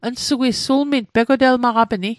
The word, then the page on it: sulming: Mi'gmaq